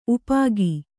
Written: ಉಪ್ಪಿಕ್ಕು

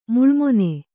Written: ಮುಳ್ಳುಮೊನೆ

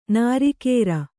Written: ನಿರಾಕಾರ

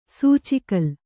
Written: ಸೂಚಿಕಲ್ಲು

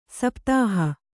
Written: ಸಪ್ತಾಹ

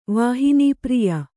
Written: ವಾಹಿನೀಪ್ರಿಯ

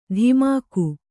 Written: ಧಿಮಾಕು

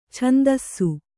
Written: ಛಂದಸ್ಸು

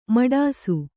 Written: ಮಡಿಸು

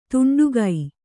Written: ತುಂಡುಗೈ

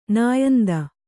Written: ನಾಯಿಂದ

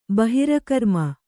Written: ಬಹಿರಕರ್ಮ

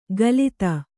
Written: ಕಲಿತ

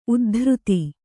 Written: ಉದ್ಧೃತ

